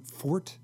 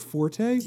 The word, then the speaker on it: forte: But do you know this term fort? – Like a characteristic that you're particularly strong in. Mm-hmm. Like for a long time, that was the only pronunciation in the dictionary was fort. But because everyone confused it with the term from music, forte, they would say, oh, that's his forte. And so they would say, and so now both pronunciations are acceptable,